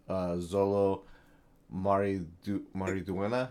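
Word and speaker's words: Xolo Maridueña: Watched it mainly because I'm a fan of the main actor, who is Zolo Mariduena. If I pronounce that hopefully correct. You pronounced it okay.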